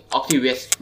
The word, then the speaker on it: Octavius: Octavius